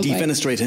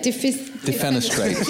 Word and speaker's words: defenestrate: Did you defenestrate him at any point? – Yes. – Good. – How do I say it? – Defenestrate.